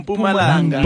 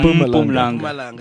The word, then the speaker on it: Mpumalanga: Is that how you say Mpumalanga? – I just giggled. – Hey, listen, dude. I don't do pronunciations. – What's the right pronunciation? – Mpumalanga. – Mpumalanga.